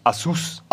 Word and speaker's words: Asus: Asus, ja, Asus heißt ja nicht Asus, ne? – Alles gut. Asus heißt ja eigentlich Asus. Asus! Das ist der, die also offizielle also Aussprache.